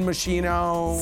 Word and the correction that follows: Moschino: Is that Moschino.